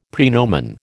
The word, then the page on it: praenomen: American English